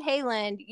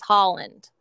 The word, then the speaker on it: Haaland: I said Haaland. You said Holland. That is the correct pronunciation. It's Holland. Deb Haaland.